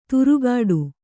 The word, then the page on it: ತುರುಗಾಡು: turugāḍu